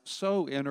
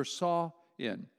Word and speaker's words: Samhain: Now, it looks like Samhain, but it's actually in Celtic pronounced So-In or Saw-In.